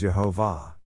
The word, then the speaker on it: Yehovah: The word Lord is Yud-Heh-Vav-Heh, the exclusive name for God, pronounced as Yehovah.